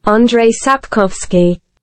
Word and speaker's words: Andrzej Sapkowski: Andreas Swarovski. Oh, sorry, I butchered. – Andrzej Sapkowski.